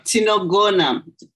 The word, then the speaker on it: tinogona: And it just, for me, um, you have this word, uh, tinongo. I don't know if I pronounce it correctly. – No, tinogona, tinogona, which tinogona.